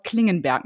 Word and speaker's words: Klingenberg: Seminarhaus Klingenberg, ne? Ist richtig ausgesprochen.